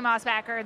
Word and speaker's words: Mossbacher: Sounds like they hoard moss. If you say Mossbacher, I say Mossbacher. This is the American bastardization of my name.